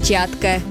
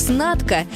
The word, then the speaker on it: Чатка: Так появилась «Чатка». Бренд, известный во всем мире и по сей день. Примечательно, что советские граждане в большинстве своем произносили его как «снатка», так как название всегда писалось на английском языке.